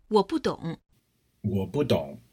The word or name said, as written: wo budong